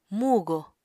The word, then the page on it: mugo: Apatani